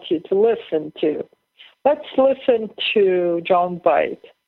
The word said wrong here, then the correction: Voight: I pronounce his name bad. Please forgive me. – Hi, I'm John Voight.